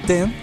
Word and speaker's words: Ten: Vitology y Teen, otra vez lo pronuncié mal. Dije adolescente, perdón. Vitology y Ten, o sea 10, ha sido incluido por la revista Rolling Stones en su lista de los 500 mejores discos en la historia.